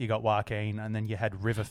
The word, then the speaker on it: Joaquin: Joaquin? How do you say his name? – Yeah, Joaquin. – Joaquin, that's right.